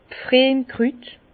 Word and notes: 's Pfriemkrüt: Bas Rhin; Herrlisheim

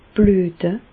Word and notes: blüete: Bas-Rhin; Reichshoffen

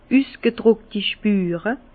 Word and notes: d' üsgedruckti Spure: Bas Rhin; Herrlisheim

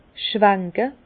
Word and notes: schwanke: Bas-Rhin; Herrlisheim

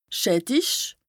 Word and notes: schättich: Bas-Rhin; Strasbourg

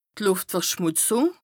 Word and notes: d' Lùftverschmùtzùng: Bas Rhin

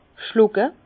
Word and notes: schlucke: Bas-Rhin; Herrlisheim

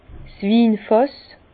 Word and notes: 's Winfàss: Alsatian; Bas-Rhin; Herrlisheim

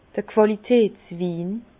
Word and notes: d'r Qualitätswin: Bas-Rhin; Herrlisheim

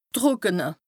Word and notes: d' Trùckene: Bas Rhin; Strasbourg

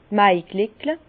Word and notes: d' Maigleckle: Bas Rhin; Herrlisheim

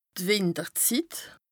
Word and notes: d' Wìnterzit: Bas Rhin; Strasbourg

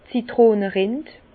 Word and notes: d' Zitronerind: Bas Rhin; Herrlisheim

